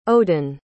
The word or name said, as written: odin